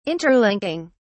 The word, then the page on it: interlinking: British English